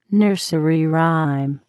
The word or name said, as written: nursery rhyme